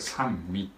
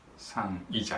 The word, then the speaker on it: 三位: これ三位って読むんですね。三位じゃなくて三位一体三位これがキリスト教用語なんです。